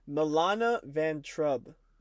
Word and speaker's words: Milana Vayntrub: Mila- Sorry, I said it wrong. Milana Vayntrub. There we go.